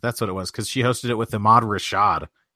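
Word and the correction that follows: Ahmad Rashad: I believe it's pronounced Amode Rashad.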